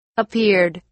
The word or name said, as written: appeared